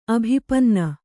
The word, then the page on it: ಅಭಿಪನ್ನ: abhipanna